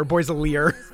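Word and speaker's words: Boy's a Liar: Boys a Lear, that's how I always say it in my head. well, you have to, because that's how they say it.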